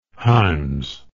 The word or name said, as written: himes